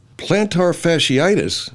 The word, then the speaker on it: plantar fasciitis: Plantar fasciitis? – Let's hear that again.